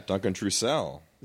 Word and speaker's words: Trussell: Yeah, but Trussell is Trussell sounds like Howard Cosell. – Yeah, Duncan Trussell.